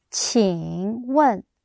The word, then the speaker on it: 请问: How do you say, excuse me? Jin wen. Qing. Qing wen. Qing wen. Yes.